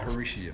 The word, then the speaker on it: Maharishi: Maharishi effect.